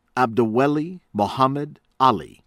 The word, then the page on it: Abdiweli Mohamed Ali: American English